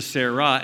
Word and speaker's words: Sarah: And from Sarai to Sarah, and there's an H there, by the way. It's a silent H.